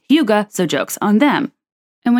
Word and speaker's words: hygge: It is called H-Y-G-G-E. It looks like it's spelled Higgy.